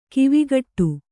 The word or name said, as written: ಕಾವುಕಟ್ಟು